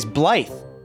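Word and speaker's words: blithe: Today's word of the day is Blythe. – Blythe Landing. – Blythe, like a Blythe Landing.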